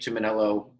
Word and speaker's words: Tumminello: If you just look up my name, Nick Tumminello, last name is spelled T-U-M-M-I-N-E-L-L-O.